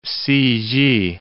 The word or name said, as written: ci-git